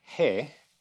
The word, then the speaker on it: he: The letter he is pronounced like our H. It's like a breath, basically. He. It also carries the connotation of a breeze, wind. And it's funny because it's pronounced that way too. It's like a breath or spirit.